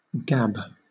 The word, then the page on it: gab: British English